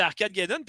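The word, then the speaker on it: Arcadegeddon: Ah, moi, j'ai reçu euh, par Dom Arc Arcade Geddon, ça se prononce comme. Ouais, ben regarde, euh, moi j'ai fini, fait que ouais, c'est Arcade Gaden.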